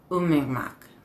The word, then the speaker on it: Umingmak: And so a word that to us looks like Umingmak is Umingmak. Like it's like in your throat.